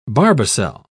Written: barbicel

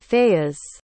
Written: fayiz

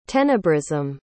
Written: tenebrism

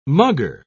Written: mugger

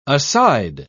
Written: aside

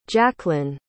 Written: jaklyn

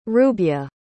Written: rubye

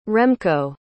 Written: remko

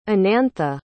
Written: anantha

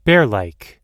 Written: bearlike

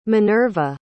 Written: minerva

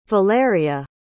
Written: valeriya

